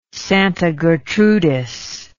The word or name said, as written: Santa Gertrudis